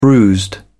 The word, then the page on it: bruised: British English